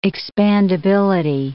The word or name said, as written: expandability